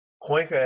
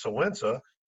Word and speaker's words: Cuenca: And then somehow Cuenca, Ecuador just popped up.